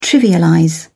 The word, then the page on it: trivialize: British English